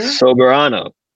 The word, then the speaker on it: Soberano: I said this wrong earlier, Sobernero Jr.? – Soberano. – Soberano. Yeah. Sober, like as in sober as in I don't drink.